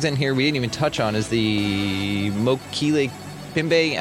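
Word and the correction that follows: Mokele-mbembe: I think it's Mokele Mbembe.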